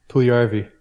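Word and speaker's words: Puljujärvi: Jesse Puljujarvi.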